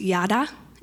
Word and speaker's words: yada: So praise in Hebrew is actually pronounced yada, and you'll see it on, we'll go back one screen, I missed one, sorry about that. But go ahead, everyone say ya. Everyone say da. Combine it, yada.